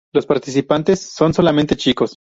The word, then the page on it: solamente: ispanyolca